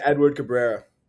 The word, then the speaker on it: Edward Cabrera: Is Edward Cabrera, is that how it's pronounced? – Yeah, Edward Cabrera.